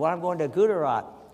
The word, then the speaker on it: Gujarat: How do you say Gujarat? – Gujarat. – See, Gujarat. Not, I do it wrong.